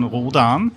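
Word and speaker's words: Rhodan: Ich kann mich erinnern, ich habe mir ein Hörspiel angehört und da war so die Diskussion oder irgendein Interview, das heißt nicht Roden, sondern Rodan.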